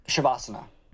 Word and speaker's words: Savasana: Shavasana. – Shavasana? – It's like Shavasana. – Shavasana. – S-A-V-A-S-A-N-A. Shavasana. Shavasana.